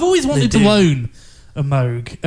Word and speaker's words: Moog: I think she was one of the first sort of pioneers to really bring the Moog synthesizer, because that's how you say it, Moog, not Moog.